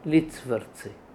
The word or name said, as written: Litsvrtsi